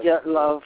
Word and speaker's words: Dyatlov: How are you pronouncing it? Dyatlov? Dyatlov? – Yes, it's Dyatlov. – Dyatlov. – Yeah, and I'm not a Russian speaker, but that's kind of the Dyatlov.